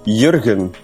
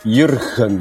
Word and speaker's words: Jürgen: Yeah, well, see, that's so tricky when English people, they always say the G. But you pronounce my name with a silent G, like G, Jürgen.